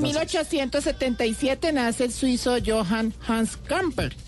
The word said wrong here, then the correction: Gamper: quien fue empresario y fundador, del Barcelona. Ah, Gamper. – Gamper. – pronuncia bien.